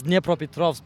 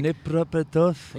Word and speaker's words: Dnipropetrovsk: It's Dnipropetrovsk, but like... – Dnipropetrovsk?